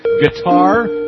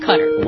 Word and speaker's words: Qatar: Is the country pronounced Qatar, Guitar, Gutter? – Well, what is it? People from the area tend to range between Qatar and Qatar, so I go with Qatar. – Why does Fox News call it Gutter? – Because that's actually more closely accurate to the Arabic pronunciation.